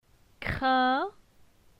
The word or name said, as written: Crin